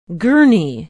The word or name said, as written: gurney